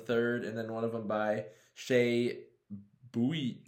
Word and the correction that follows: Buium: I think it's just Boom. – Boom. – Yeah, I'm pretty sure. – It's spelled B-U-I-U-M. So yeah.